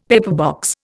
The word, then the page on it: pepperbox: Indian